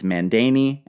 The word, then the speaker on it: Mandane: Now, I want to remind everyone, before I get too far into this, we don't really know exactly how these names would be pronounced. So for the sake of our story, it's Mandane and Astyages.